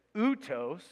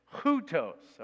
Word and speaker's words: houtos: So you don't say outos, you say houtos, all right? You put a little h on it.